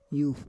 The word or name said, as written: yuf